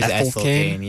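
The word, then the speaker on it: Ethel Cain: I heard you say Ethylcane, but I think it is Ethylcane, yeah. – Like ethylene.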